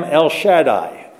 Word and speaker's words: Shaddai: I am El Shaddai, as we would pronounce it. Shaddai in the Hebrew, but I am El Shaddai.